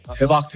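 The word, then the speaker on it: Tebak (대박): The first time you taught me how to say it's lit, which is teba. – Tebak, tebak.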